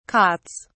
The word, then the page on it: cots: British English